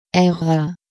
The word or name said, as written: Aera